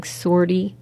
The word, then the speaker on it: sortie: So they just have helicopter doing sortie. Am I pronouncing that right? – Yeah.